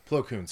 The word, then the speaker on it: Plo Koon: But, like, you looked at, was it Klo Foon? Is that how you say it? The guy who had like the mask. – Oh, Plo Koon. – Plo Koon, sorry.